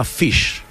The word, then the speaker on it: Phish: Sto parlando di una band che si chiama Fish. Fish è scritto non come pesce in inglese, cioè F-I-S-H, bensì come P-H-I-S-H. La pronuncia è identica, ma naturalmente la grafia è diversa.